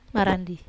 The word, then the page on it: marandi: Wamesa